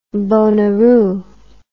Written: bonaroo

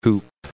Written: whoop

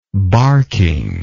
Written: barking

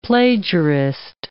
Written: plagiarist

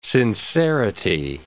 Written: sincerity

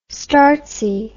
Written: startsy

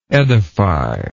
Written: edifyingly

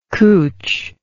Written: cooch

